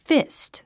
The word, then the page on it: fist: físt; フィスト